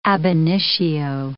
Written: ab initio

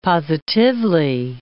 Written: positively

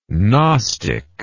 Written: gnostically

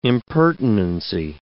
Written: impertinency

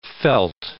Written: felt

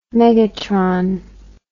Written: megatron

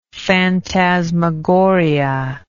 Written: fantasmagoria

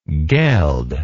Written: gelder

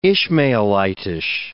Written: Ishmaelitish

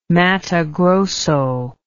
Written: matto grosso

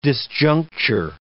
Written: disjuncture

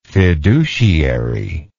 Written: fiduciarily

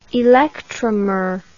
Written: electromer

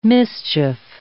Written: mischief

